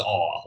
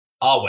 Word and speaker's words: Awe: Andrew Awe, who seems to be making it is Awe, like the word Awe, right? I'm saying that right? – I believe it's Awe. – Awe, okay.